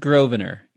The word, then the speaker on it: Grosvenor: Is it pronounced Grosvenor? – It's pronounced Grosvenor. Gil Grosvenor was the editor for Nat Geo.